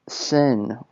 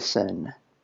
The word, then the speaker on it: Synn: So you can call me Sin, or DJ Sin, which is spelled S-Y-N-N.